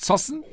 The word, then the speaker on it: Zossen: Spricht man das so aus? Zossen? – Ja, Ihr seid wirklich lustig und man könnte euch schon fast das Prädikat Volkspodcast umhängen.